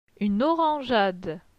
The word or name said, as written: Une orangeade